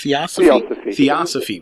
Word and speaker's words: Theosophy: Theosophy.